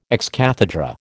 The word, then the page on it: ex cathedra: American English